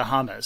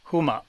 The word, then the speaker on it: hummus: It's closer to hummus. So it's like huma.